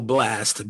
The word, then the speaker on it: Blxst: Someone named Blast, but the A is an X. So I believe it's still Blast, BLXST, Pooh Shiesty, Tusi, double I's, double O's, in case you're wondering how to spell that, Coi Leray, and Flo Milli.